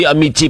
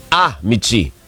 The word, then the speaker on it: Amici: Ayun daw ang tamang bigkas. Nasa unang ano daw, si Labol daw yung ano, diin. So hindi Amici pala. Amici. Oo, ganun daw. Ano yung tama? Amici.